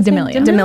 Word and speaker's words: D'Amelio: D'Amelio.